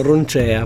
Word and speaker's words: Roncea: Bella, vediamo se lo pronuncio bene, per Roncea. – Sì, perché già durante l'intervista gli ha detto Roncea, lui ha detto no, ma vai tranquillo, in italiano Roncea proprio.